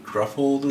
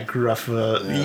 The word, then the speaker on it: Gruffudd: Ian Gruffudd. – Yeah, Gruffudd.